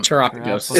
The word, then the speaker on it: Terapagos: Anyway, Terapagos is how they pronounce it in the trailer.